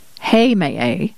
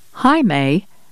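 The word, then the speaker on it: Heimaey: There are at least three pronunciations of this island: Heimaye, Heimaye, and Heimaye.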